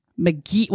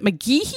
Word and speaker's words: McGehee: So again, this Snapped is season 21, episode 10, about Dawson McGee. Was it McGeehee? – They just called it McGee. – Yeah, McGee, but it's spelled McGeehee, but it's McGee.